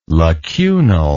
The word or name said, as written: lacunal